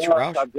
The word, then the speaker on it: Rouch: Roush's is how I pronounce it. Is that how they pronounce it? R-O-U-C-H, Roush? – Jack Roush.